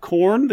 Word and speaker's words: Khorne: Nope, it's spelled K-H-O-R-N-E. – Oh, but it still sounds like corn, like maize. – It has, yeah, it's. I did not mispronounce it. I pronounced it 100% correctly. It sounds exactly like the food and or band.